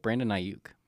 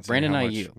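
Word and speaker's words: Brandon Aiyuk: Brandon Ayuk for the 49ers.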